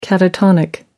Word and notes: catatonic: American English

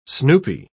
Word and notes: snoopy: British English

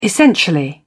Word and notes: essentially: British English

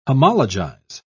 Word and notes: homologize: British English

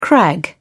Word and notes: crag: British English

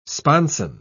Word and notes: sponson: British English

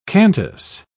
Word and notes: cantus: British English

